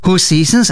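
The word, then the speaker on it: Hoseasons: Ho Seasons, as it is pronounced,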